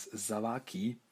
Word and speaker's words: Oroku Saki: Uh, Oroku Saki uh, wird im Ofa ausgesprochen als Sawaki.